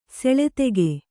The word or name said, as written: ಸೆಳೆತೆಗೆ